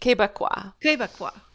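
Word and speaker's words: Québécois: Quebecois.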